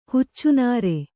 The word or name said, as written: ಹುಚ್ಚುನಾರೆ